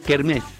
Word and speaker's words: kermesse: Se escribe kermesse, pero se dice kermés.